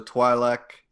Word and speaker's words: Twi'lek: You have Hera, who's a Twi'lek, if that's how you pronounce it. – Yep.